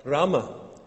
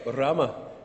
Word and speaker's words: Rama: Little girl in the front of your order of service is called Rama, or if you can roll your R's, Rama. I think there's a wee bit of a roll there, so if you're from Scotland or North Antrim, you shouldn't have a problem doing that.